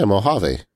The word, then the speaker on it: Mojave: Yeah, Mojave.